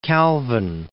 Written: Calvin

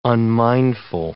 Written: unmindful

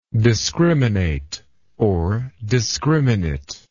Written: discriminately

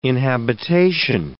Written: inhabitation

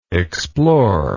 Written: exploringly

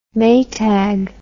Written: Maytag

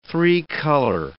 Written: three-color

